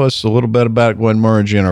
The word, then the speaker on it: Glenmorangie: Glenmorangie. – Yeah. Yes. – Morangie. – Yeah. – Rhymes with orangey.